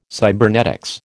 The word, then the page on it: cybernetics: American English